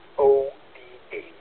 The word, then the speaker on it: BOTA: Scuzați intenția nevinovată de a face o rimă, pentru că normal corect pronunția este BOTA, adică Beaches on the Air.